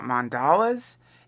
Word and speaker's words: mandala: I'm glad that you're talking about this, because I've been reading this Chogyam Trungpa book on it's a book about mandalas and how do you say it, man? Mandala. That's how you say it. It's a book about, not Nelson Mandela, mandala.